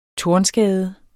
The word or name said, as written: tornskade